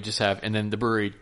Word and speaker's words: Bruery: That's the name of the brewery is The Brewery. And it's not spelled, you know, brewery. It's spelled B-R-U-E-R-Y. – Yeah. – So. – Brewery. – Brewery.